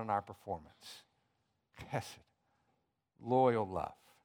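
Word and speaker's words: Hesed (חֶסֶד): It's the word hesed. You have to say it like you're getting something out of your throat, okay? Hesed.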